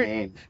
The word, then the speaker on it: Samhain: Cochran, Samhain. – Well, no, it's actually pronounced Samhain. That's how you say it. So it's Celtic. – Seriously? – Yep, that is that is actually correct.